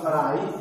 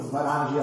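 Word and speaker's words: Swaraj: sarai, o sbaraggi a seconda della pronuncia, che letteralmente significa queste due cose vanno insieme, eh, sadiagra e svarai Svarai significa due cose.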